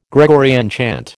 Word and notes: Gregorian chant: American English

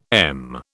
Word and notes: m: British English

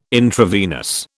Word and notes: intravenous: British English

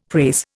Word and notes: prase: Indian

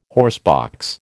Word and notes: horsebox: American English

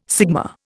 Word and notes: sigma: Indian

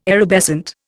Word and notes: erubescent: Indian

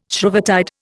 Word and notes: Shrovetide: Indian

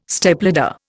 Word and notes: Stepladder: Indian

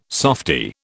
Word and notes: softie: British English

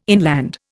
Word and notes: inland: Indian

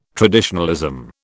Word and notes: traditionalism: British English

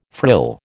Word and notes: frill: American English

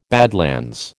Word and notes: badlands: American English

